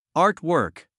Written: art work

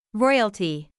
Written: royalty

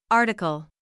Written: article

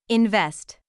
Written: invest

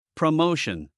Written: promotion

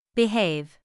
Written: behave

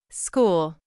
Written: school

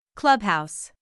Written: clubhouse

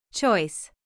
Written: choice

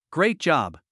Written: great job

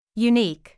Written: unique

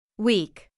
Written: week

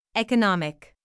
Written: economic

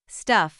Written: stuff

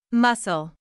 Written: muscle

